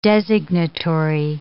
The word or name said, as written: designatory